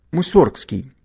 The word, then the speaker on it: Мусоргский: Хотя насчет ударения в фамилии композитора нет единого мнения. Сам музыкант родился в Псковской губернии в 1839 году, то есть 181 год назад, и произносил свою фамилию с ударением на первый слог и без буквы «Г» – Мусоргский. Но в какой-то период Модест Петрович стал произносить ее по-другому – Мусоргский.